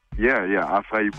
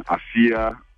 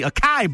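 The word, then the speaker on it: açaí: It's made from, acai bowl. I was pronouncing it wrong. I was calling it acia.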